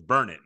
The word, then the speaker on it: Burnet: My pronunciation was off. So it's Burnett. I was saying Burnett.